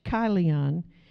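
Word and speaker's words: Chilion: Now, it looks like Kilian, but it's actually pronounced with the C-H, Kilian.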